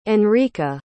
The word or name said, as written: enrica